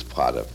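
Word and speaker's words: Prater: I don't know what they say in English, Prada, but the pronunciation is Prada.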